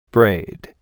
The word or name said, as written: braid